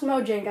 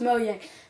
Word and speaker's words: Mojang: I can't pronounce it Mojang. It's not pronounced Mojang, guys. It's pronounced Mojang.